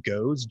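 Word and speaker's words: Goze (瞽女): I actually read about, I think it's pronounced Goze, G-O-Z-E, in Japan.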